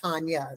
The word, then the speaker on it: Tonja: Tonja and I spell our names the same, but mine is pronounced Tonja.